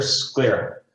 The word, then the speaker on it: sclera: Spelled S-C-E-R-L-A. – Okay. Answer, sclera. – Sclera is right. Although misspelled, you get the 20 points because you pronounced it correctly.